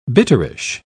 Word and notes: bitterish: British English

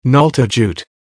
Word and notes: nalta jute: American English

